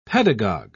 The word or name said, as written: pedagogue